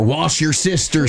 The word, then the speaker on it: Worcestershire: Worcestershire. Or wash your sister sauce.